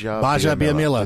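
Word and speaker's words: Gbaja-Biamila: Baja Biamila. The G is silent.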